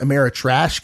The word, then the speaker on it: Ameritrash: There's Euro games and then now what are called Amerithrash games. It's actually pronounced Ameritrash games.